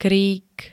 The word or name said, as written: krík